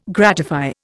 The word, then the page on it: gratify: Indian